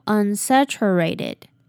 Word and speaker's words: unsaturated: 接着后面的 ae 发 a 的音，念作 ray，那并且会把这里的 t 弱化，所以这个字念作 unsaturated unsaturated。好，以上就是发音的部分，那我们就再来念一次句子吧。 One recent study found that drinking full-fat dairy was associated with a lower risk of diabetes. So while unsaturated fats are better, saturated fats aren't entirely useless.